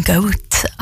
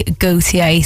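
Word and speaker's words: Gotye: I have been calling him Goat. I thought that's how you pronounced it. But no, it is actually Gautier.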